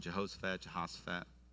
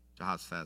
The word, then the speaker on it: Jehoshaphat: Some of you might pronounce it Jehoshaphat. Jehoshaphat is how I'm going to do it.